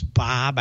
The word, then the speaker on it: Bob: If you don't remember me, my name is Bob.